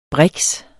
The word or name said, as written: briks